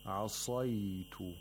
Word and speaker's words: عَصَيْتُ: عَصَيْتُ عَصَيْتُ. The sad has tafkhin. You have to pronounce it with the root of the tongue raised and the pharynx constricted.